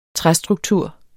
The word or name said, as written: træstruktur